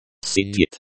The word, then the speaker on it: Сидит: Сидит. Сидит, the I, sounds like the I, in, machine.